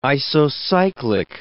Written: isocyclic

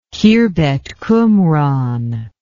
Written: Khirbet Qumran

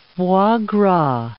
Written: foie gras

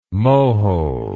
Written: Mohole

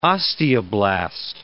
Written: osteoblast

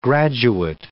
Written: graduate

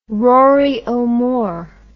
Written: rory o'more

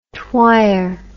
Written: twyer

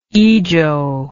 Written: ijo

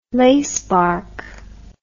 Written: lacebark

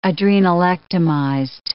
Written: adrenalectomized

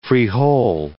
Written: frijol

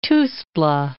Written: Tuxtla

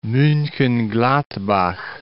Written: Munchen-Gladbach

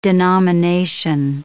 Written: denomination

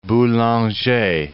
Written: Boulanger

